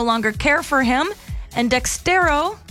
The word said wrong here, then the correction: Dexerto: let me say this right, Dexerto. There we go, Dexerto.